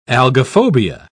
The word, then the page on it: algophobia: British English